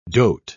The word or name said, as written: dote